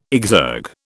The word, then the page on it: exergue: British English